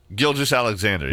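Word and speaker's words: Gilgeous-Alexander: Gilgeous Alexander, yeah.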